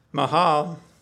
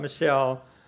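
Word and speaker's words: Michal: Now you could say this any way you want, but it's Mahal is what the proper pronunciation is, not Michelle. Mahal. But I'm going to say Michelle because that's a way we can say it.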